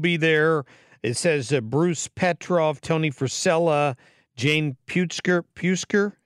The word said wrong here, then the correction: Puskar: Am I pronouncing that right? – Puskar